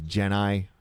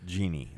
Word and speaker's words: genii: also known as genii. I think some people mispronounce it. – Not to be pronounced as genie. Or yes, genie is a mispronunciation.